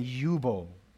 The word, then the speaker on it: Jubal: In Hebrew, they're pronounced Yabal and Yubal.